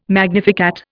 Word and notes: magnificat: Indian

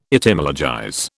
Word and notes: Etymologize: British English

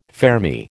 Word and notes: fermi: American English

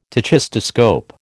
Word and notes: tachistoscope: American English

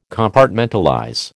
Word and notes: Compartmentalize: American English

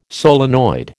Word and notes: solenoid: American English

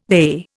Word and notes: they: Indian

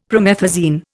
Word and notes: promethazine: Indian

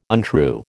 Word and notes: untrue: American English

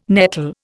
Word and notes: nettle: Indian

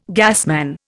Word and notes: gasman: Indian

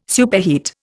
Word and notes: superheat: Indian

